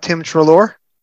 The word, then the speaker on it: Tim Treloar: You now are also in a line of Third Doctor stories opposite, and I may pronounce the name wrong because I've never heard it spoken aloud, Tim Treloar? – Yes, that's right. I'm perfect pronunciation.